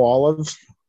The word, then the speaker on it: Olive: It's Joe Olive. Good afternoon, everybody. O-L-I-V-E.